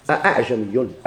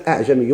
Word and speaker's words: أأعجمي: كيف دي قروها؟ أأعجمي أأعجمي هيدا لا تسحكي يقوى أأعجمي لينطقوا جوج الهمزة هيدا هذا هو التسحكان.